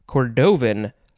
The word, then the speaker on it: cordovan: And I want to say cordovan leather, but you know, if we look back at yesterday's episode, it's the emphasis is on the first syllable.